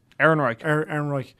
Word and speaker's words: Ehrenreich: And I mean, like Alden Ehrenreich, have I said his name right? – Ehrenreich.